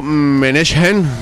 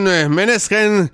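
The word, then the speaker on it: Måneskin: Por cierto, el nombre del grupo, Menechen, creo que lo digo bien, pronunciado en danés, ya que es una palabra danesa.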